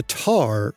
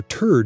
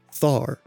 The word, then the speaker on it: Thar: And it's the Thar or Tur Desert, not Thar. Thar is spelled T-H-A-R, but it's pronounced with just a regular T sound. So, Namib Desert and Thar Desert.